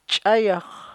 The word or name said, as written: ch'ayax